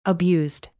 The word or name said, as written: abused